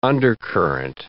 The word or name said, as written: undercurrent